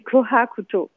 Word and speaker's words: kohakuto: Alors, il faut que je le prononce bien, mais en japonais, ça se dit kohakuto.